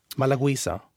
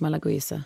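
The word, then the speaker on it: Malagousia: Sokos Malagouisa heter, hur talar man den där druvan? Malagouisa. – Ja, det skulle jag nog uttala det, samma sak, Malagouisa.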